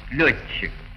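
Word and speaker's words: лётчик: Группы ДЧ и ТЧ произносятся как двойное Ч. Находчивый, укладчик, опрометчивый, летчик.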